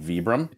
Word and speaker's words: Vibram: Probably the correct pronunciation is indeed Vibram.